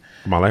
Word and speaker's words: Kamala: Also, Kamala Harris. Mm-hmm. I say her name correctly. – Kamala Harris.